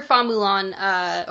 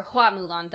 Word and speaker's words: Hua Mulan: That's where Fa Mulan, or Hua Mulan, that's actually the correct pronunciation, the original legend came from.